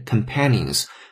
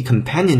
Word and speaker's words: companion: 主语是 many，很多人 think of dogs，他们把狗认为是 loyal, love-filled companions，是忠诚的，是充满了爱心的或者是有爱的 companions，这些伙伴。注意 companion 这个单词的发音，如果原来只是在视觉上认识它，可能会读成类似于 companions 这样的声音，不对，它的读音不是跟 company 一脉相承下来的，它要中音发生了变化，叫 companion，companion。